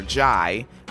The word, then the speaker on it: Ghey: I'm saying Ghey now instead of Ghey because G-H-E-Y, that looks like Ghey or Ghey, like silent H, right? But I found some material published to the YouTube with some folks from the UK talking. And they said her name and said Ghey, Ghey, Ghey.